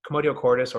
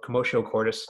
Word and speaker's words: commotio cordis: And you guys are probably aware of this already, but basically commotio cordis, or commotio cordis, it's pronounced two different ways, is basically a disruption of your heart's rhythm.